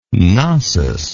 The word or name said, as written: Cnossus